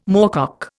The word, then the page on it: moorcock: Indian